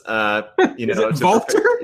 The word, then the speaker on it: Walter: Walter? – Yeah, that's how you say it. He's Austrian.